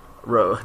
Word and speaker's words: Rogue: Is he going to put Rouge on the team? – Rogue.